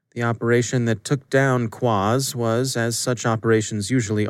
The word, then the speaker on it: QQAAZZ: Department of Justice announced yesterday that it had indicted 14 members of Quaz. We're just going to go with that pronunciation since it's spelled Q-Q-A-A-Z-Z, so your guess is as good as mine.